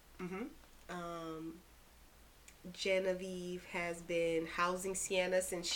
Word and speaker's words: Genevieve: We find out that one Sienna and Genevieve, I don't know how to pronounce her name. – It's Genevieve. – Genevieve, not mm-hmm. Genevieve. Genevieve. – Genevieve. – Genevieve. – Yeah. – Gen of Eve. Genevieve.